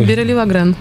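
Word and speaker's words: Biréli Lagrène: Ну что, послушаем тогда Берилли Лагрен. Я правильно произношу? Нет? У меня есть французский. – Да, Берилли Лагрен.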